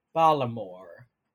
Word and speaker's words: Baltimore: So Baltimore, the Baltimore accent is exemplified in the way they don't say Baltimore. It's like Balamore.